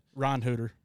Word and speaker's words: Reinhardt: Do you pronounce the D? – No. It's like a Reinhooter. – Reinhooter. I think it's more of like the German, like the original German spelling, except we reverse the I and the E.